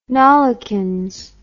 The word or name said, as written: Nollekens